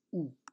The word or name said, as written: u